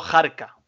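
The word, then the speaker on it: harca: Habrá Utelo Express y me ha venido otra palabra de H aspirada que es cuando decimos jarca, esa jarca que realmente se escribe con H que es arca y significa expedición irregular de tropas indígenas marroquíes.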